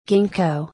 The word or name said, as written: gingko